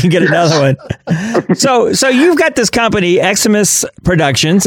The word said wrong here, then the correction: Eximius: Am I saying that right? – No, you're not. It's Eximius.